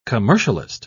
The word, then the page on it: commercialist: British English